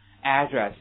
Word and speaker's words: address: 还有另外一个你们平常经常见到的地址这个单词怎么读 address，所有的美国人会给它重音调一个位置，读成什么 address，一定要记住，美国人会读成 address 有一个单词的区别非常大，有一个单词的区别呢非常大的时间表这个单词知道吗？